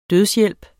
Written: dødshjælp

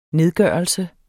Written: nedgørelse

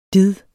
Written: did

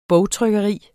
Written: bogtrykkeri

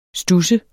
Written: studs

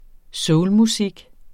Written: soulmusik

